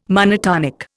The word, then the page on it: monotonic: Indian